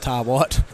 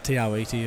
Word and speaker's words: Te Awaiti: So that's the proper name. Some pronounce it Tawat, but Tiawiti is the proper name.